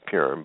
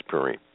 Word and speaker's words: Purim: I know it's pronounced Purim, but that's the English way, the Hebrew way of pronouncing it is Purim.